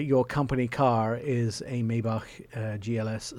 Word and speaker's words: Maybach: It is a brand new Mercedes Maybach GLS 600. Maybach, not Maybach. – Maybach. – Most Americans butcher it.